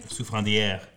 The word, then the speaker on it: Souffrandière: But then they have La Souffrandière. Is that how did I say it right? – Souffrandière.